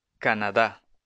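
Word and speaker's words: Canadá: カナダ。わかるかな？日本語のアクセントと逆です。カナダ。 – カナダ人。 – カナディエンセ。女性も男性も同じ。 – 英語。 – イングレス。実は、カナダでは、フランセス、フランス語も話されます。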